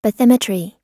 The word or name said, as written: bathymetry